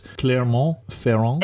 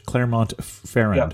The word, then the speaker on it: Clermont-Ferrand: One more time with the name of the capital, because unfortunately we don't pronounce it. So it's Clermont-Ferrand? – Exactly. – Correct. Yeah. – Okay. Or if we're spelling it in English, Clermont-Ferrand. – Yep. – You got it.